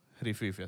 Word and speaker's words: Rififi: ¿Se pronuncia así? – Rififi, así mismo.